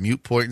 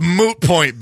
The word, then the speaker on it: moot point: Like I told you guys the story when I said mute point instead of moot point. And then I tell somebody, it's a mute point. And they were like, That's not how you say it. – That's not how you say it. – It's moot point.